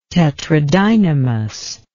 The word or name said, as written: tetradynamous